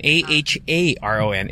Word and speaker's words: Aharon: In its original Hebrew, Aaron, A H A R O N, is pronounced as three syllables. Aharon.